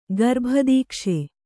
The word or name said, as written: ಗರ್ಭದೀಕ್ಷೆ